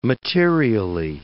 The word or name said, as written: materially